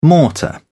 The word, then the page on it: mortar: British English